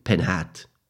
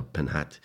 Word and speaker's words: Penjat: Near the town of Amposta, we once again crossed the river Ebro and entered the town via the famous bridge Pont Penhat. I don't know if I pronounced that correctly, but it's P-E-N-J-A-T, Pont Penjat or Penhat, which translated means nothing more than suspension bridge.